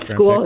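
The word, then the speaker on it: Skule: It's going to be Justin School. I'm mispronouncing his name. I apologize. But the rookie. – No, it's School. It's pronounced like School.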